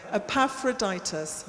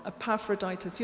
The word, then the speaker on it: Epaphroditus: Epaphroditus. I knew I was going to say that wrong. Epaphroditus. You're the one we're trusting with the money.